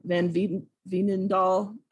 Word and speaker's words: Van Veenendaal: I have Van Veenendaal.